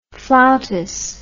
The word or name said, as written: flautas